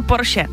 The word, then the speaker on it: Porsche: A pak tady ještě Porsche, jo, to my Češi říkáme skoro ze všech jako národů jediný správně. Všichni říkají Porsche špatně, ale je to opravdu Porsche, takže tady si můžeme jako poklepat na rameno, že to říkáme dobře.